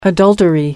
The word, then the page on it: adultery: American English